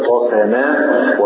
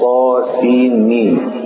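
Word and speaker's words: طسم: ميم كما سبق في سائر السور هذه الحروف المقطعة تكتب طاسماء وتنطق طاسين ميم،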